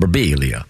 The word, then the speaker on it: Birbiglia: But when the first time he came in here, he pointed out that his name probably should be pronounced Birbiglia.